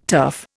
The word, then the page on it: turf: Indian